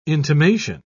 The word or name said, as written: intimation